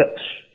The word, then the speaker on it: Klipsch: Klipsch, yep.